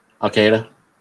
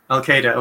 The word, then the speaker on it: Al-Qaeda: Is Al-Qaeda or Al-Qaeda? How do you pronounce that? – Al-Qaeda. – Al-Qaeda, okay.